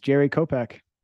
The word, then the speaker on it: Jerry Kopack: Yeah, it's Jerry Kopack.